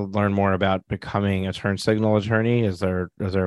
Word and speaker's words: Turn Signl: And Turn Signl is spelled T U R N S I G N L. So it's spelled turn signal without an A uh, that's because there's a lack of access to justice.